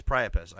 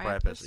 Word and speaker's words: Priapus: There's a new dickumentary called The Temple of Prius. Priapus. – Priapus. – I think it's Priapus, actually. I think it's pronounced Priapus. Yeah, it's Priapus.